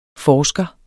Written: forsker

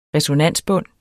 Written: resonansbund

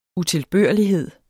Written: utilbørlighed

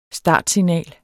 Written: startsignal

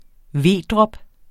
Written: vedrop

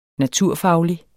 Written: naturfaglig

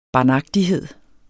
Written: barnagtighed

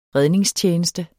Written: redningstjeneste

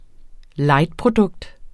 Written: lightprodukt

